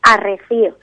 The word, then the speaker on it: arrecido: Que nosotros no pronunciamos esa D. Y decimos arrecío. Esa, la D intervocálica, que es la D que se da entre las vocales finales,